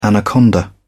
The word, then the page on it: anaconda: British English